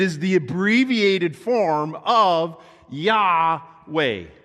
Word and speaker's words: Yahweh: The word Lord, often in English text, is capitalized, where it is written in Hebrew, these four-letter words, YHWH. Okay? We ascribe those American alphabet letters to that, and we pronounce it Yahweh.